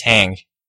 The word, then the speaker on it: Hung: Yeah, while we're speaking of pronunciation, my name is spelled H-U-N-G, but it's pronounced Hang, which is crazy.